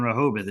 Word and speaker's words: Rehoboth: Rehoboth. Did I say that correctly? – Yep.